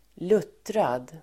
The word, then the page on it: luttrad: [l'ut:rad]